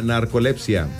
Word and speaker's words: narcolepsia: Día Mundial de la Narcolepsia. Es una enfermedad. – Pero sí se dice así.